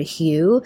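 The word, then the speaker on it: HU: And one of my favorite ways to meditate is simply to chant the word Hugh. It's H-U, pronounced like the color Hugh or the name Hugh.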